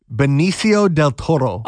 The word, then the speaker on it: Benicio del Toro: Benicio del Toro. – Oh, no. That was pretty good. – Oh, and I even did the roll in the eye. – Yeah, that was fancy. – Benicio del Toro.